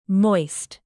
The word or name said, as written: moist